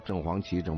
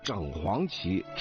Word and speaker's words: 正黄旗: 因为北京啊好几个地名啊，就是比如说正黄旗啊、正蓝旗啊，这地名是这个，所以呢很多人也就念了啊正黄旗、正白旗。实际上它正确的读音是整啊，是整黄旗、整白旗啊、整红旗、整蓝旗啊。